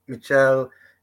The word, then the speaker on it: Michel: I, I think some things you guys haven't touched on that I'd like to touch on about Michel, which I think I, I was calling him Michelle for years, but it's actually how Costa pr- pronounces it uh, ah, Michel.